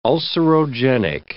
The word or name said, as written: ulcerogenic